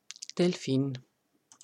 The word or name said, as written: Delphin